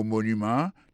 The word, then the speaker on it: monument: Pou mo monument, il tombe sou premier syllabe la. The Washington Monument. Monument, se kon se wè di monument an anglè.